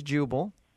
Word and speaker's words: Jubal: Okay, my name is Jubal. Right, you got that? – Jubal. How do you spell that? – J-U-B-A-L. – J-U-B-A-L. Uh-huh.